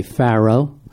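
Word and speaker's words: Fårö: This was filmed on the island of, help me with my pronunciation, Fura. We would say Pharaoh.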